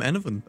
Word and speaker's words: Enevun: I'm Enovan.